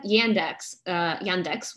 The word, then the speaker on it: Yandex: And because they had Yandex, which is how you say it in Russian, the Russian search engine.